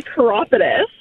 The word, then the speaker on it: chiropodist: It's actually a chiropodist, kind of like chiropractor.